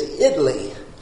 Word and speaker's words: Italy: And I was talking to one man who was from the town of Italy, Italy, Texas, but he said it's not said Italy, it's Italy. And so it was interesting to get to know those people and be a part of it.